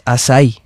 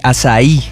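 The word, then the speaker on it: açaí: Hay gente que dice acai. Porque se escribe con C. – Acai no, pero es asai. – Es más, asai. – ¿Tú comes asai? – Yo he comido asai, no me gusta. – Bueno, bien, pero ¿sabes cómo se pronuncia? – Se pronuncia así, Azaí.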